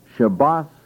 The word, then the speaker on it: Shabbaton: There is another word which is pronounced Shabbaton.